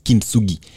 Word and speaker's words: kintsugi: So, of course, this is a Japanese word and it's pronounced as kintsugi.